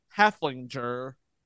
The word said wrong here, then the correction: Heffelfinger: all episode long. It is Heffelfinger. – Heffelfinger.